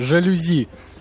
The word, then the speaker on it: жалюзи: Друзья, жалюзи. Э, слог на последнее ударение в словах не русского происхождения.